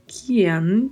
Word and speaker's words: quien: O I precisa ser pronunciado e o E um pouco mais fechado. Não podemos abrir a letra E, né? É E. A pronúncia é sempre E. Então falamos Kian.